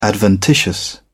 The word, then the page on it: adventitious: British English